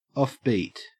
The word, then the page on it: offbeat: Australian English